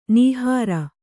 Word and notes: ನಿಹಾರ: kannada

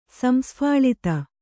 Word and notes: ಸಂಸ್ಫಾಳಿತ: kannada

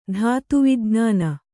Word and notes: ಧಾತುವಿಜ್ಞಾನ: dhātu vijñāna

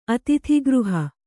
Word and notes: ಅತೀತಗ್ರಹ: atītagraha

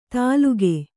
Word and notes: ತಾಲುಗೆ: tāluge

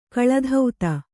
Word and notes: ಕಳಾತೀತ: kaḷātīta

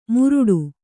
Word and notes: ಮುರುಟು: Kannada

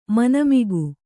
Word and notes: ಮನೆಮಗ: mane maga

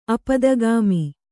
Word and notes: ಆಪ್ತಾಗಮ: āptāgama

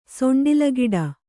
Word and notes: ಸೊಂಡ್ಲಿಗಿಡ: Kannada

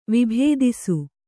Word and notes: ವಿಭೇದಿಸು: vibhēdisu

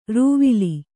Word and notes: ರೂವಿಲಿ: rūvili; Kannada

